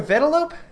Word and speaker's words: Vanellope: Vanellope is Did you call her Vanellope? – Oh my god, did I say it wrong? – It's Vanellope.